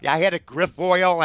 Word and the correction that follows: Grifol: I've been doing it all. I've had 19 different pronunciations. Grifol, good.